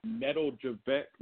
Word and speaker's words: Nedeljkovic: And I know we have, I always butcher this kid's name. It's some weird, like, Nikajovic or what? I know I butchered it. – Nedeljkovic.